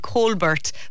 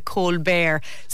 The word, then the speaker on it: Colbert: I thought was Stephen Colbert, but it's pronounced apparently in America, Colbert.